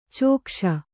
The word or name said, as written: ಚೆಕ್ಕಿಸು